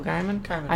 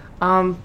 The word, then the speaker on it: Gaiman: Gaiman. – I know I'm always going to say it wrong. – I think it's Gaiman.